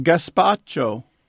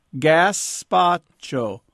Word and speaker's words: gazpacho: Gazpacho. Gazpacho.